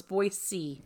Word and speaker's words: Boise: No, you have to say it right. It's Boise. – Boise? – Boise? – Boise, Idaho. Idaho. I was educated.